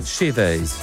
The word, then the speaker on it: Shivers: Allora, essendo lui inglese, quindi britannico, sì. Ed Sheeran con Shivers. Shivers. – Shivers. – Shivers. Se fosse americano, Shivers.